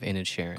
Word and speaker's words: Inisherin: You heard it for the first time here, me saying the title of this movie correctly, The Banshees of Inisherin.